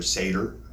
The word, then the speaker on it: seder: Uh, uh, I ended up at two events that sort of were like a um, were like a, a, Passover cedar, and uh, did that or seder, I think is how you say it.